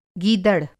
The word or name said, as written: गीदड़